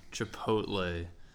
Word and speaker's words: Chipotle: First off, it's Chipotle, not Chipotle. You're reversing the T and the L there. So if you're gonna crap on this place, at least pronounce it correctly. Chipotle.